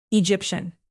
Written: egyptian

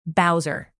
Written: bowser

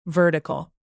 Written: vertical

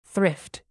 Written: thrift

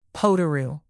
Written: potoroo

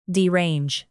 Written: derange

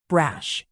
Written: brash

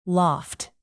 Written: loft

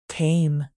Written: tame

